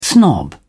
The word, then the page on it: snob: British English